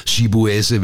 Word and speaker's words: Chibueze: Så blev det det flotte navn som Chibueze, hvilket jo betyder Gud er stor, undskyld, Gud er konge på sproget Igbo, udtalt som Chibueze.